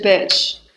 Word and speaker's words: bitch: Beach 里的 e 是长音，所以是 beach。而 bitch 中的 e 是短音，所以是 bitch。